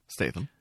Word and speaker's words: Statham: As many people have pointed out, there's a subplot of Jason Strahan and this little girl. Statham. – Yeah, I'm sorry. I That's can okay. never say his name right.